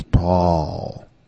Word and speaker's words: Paul: Like, my name is Paul.